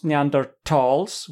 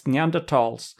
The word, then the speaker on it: Neanderthals: And yes, it's supposed to be pronounced Neanderthals with a hard T. Isn't that right, Annika? – Yes, because it's referring to a valley. – It's the valley. Mm-hmm. So don't forget about the H. That's just for English people or English-speaking people. It's Neanderthals.